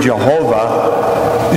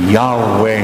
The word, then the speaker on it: Yahweh: Frankly now, the word Jehovah is just a bad pronunciation. The word is Yahweh, and it means I am.